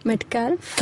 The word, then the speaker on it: Metcalf: The L is silent. Metcalf.